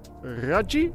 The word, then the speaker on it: Raggi: Ze, maar vooral Damiano en gitarist Thomas Raggi, zeg ik dat goed? – Ja.